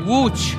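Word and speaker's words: Łódź: Wooch. – Wooch. Wooch. Yeah, you never guess it when you read it, but it's Wooch.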